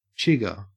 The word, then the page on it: chigger: Australian English